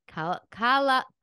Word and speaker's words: Calvaneso: Her name is Annie Calvaneso.